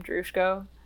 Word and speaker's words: Drushko: That's how you pronounce my last name, Drushko. Just like that.